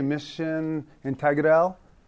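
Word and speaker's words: misión integral: Mission integral is a phrase. Anyone Spanish here? Did I butcher that? Was that okay? Mission integral? Is that pretty close? Okay, thank you.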